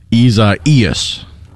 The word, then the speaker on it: Isaias: And the prescribed pronunciation is Isaias.